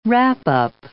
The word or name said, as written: wrap-up